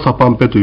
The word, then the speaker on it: サパンペ: 冠ですけど、まあ、通称はサパンペというふうに発音しますけれども、まあ、これは本当このテキストにも書いてあります通り、まあ、イオマンテ、その他いろいろな儀式をするときに男性が被り物ですね。